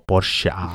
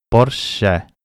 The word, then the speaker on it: Porsche: el último momento del episodio, al final dice como Porsche. ¿No? Y es cierto, es entra esta E abierta, sin darle más tema. Porsche. Pero sin A ni seré e, castellana, ¿vale?